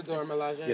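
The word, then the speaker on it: Dora Milaje: The Dormilaje.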